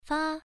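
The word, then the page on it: fa: tone 1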